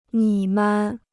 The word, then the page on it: 你们: nǐ men